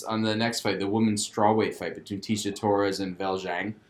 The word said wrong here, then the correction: Weili Zhang: Wiley Zhang, I believe.